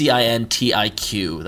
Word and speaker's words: Cintiq: It's Cintiq. That's how it's pronounced.